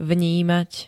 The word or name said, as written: vnímať